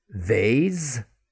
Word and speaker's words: vase: This word now has two pronunciations. – Vase, vase, vase, vase. You put the flowers in it. It's at Valentine's Day. Vase, vase. But still got that v sound. Vase, v, vase. No matter which way you pronounce it, Still the same sound.